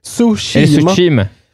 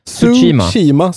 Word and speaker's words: Tsushima: Det ska inte vara något ljud. Vi ska komma överens om det här nu. Tsushima. Tsushima som sushi. Tsushima, tror jag.